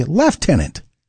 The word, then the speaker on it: Lieutenant: It's spelled C-O-L-O-N-E-L, but pronounced Colonel. And right behind it is Lieutenant. In Canada, we say Lieutenant. There's no F there.